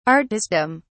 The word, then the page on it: artistdom: British English